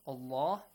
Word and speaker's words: Allah: Pronounce the word Allah with a heavy sound. Allah.